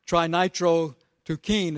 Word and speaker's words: trinitrotoluene: Trinitrotoluene is how it's spelled. I mean, how it's pronounced.